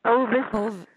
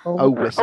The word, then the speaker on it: ovis: Now that's spelled P-I-S-C-I-S. Piscis. Okay. – Okay. – What's the word for sheep? – Ovis. – Yeah, ovis. O-V-I-S.